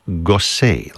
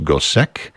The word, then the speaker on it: Gossec: Last name is spelled G-O-S-S-E-C. You might think that would be pronounced Gosek, but we know that he himself pronounced it that way because he would spell his name differently, sometimes with an E-Z on the end, an E-T, whatever it is.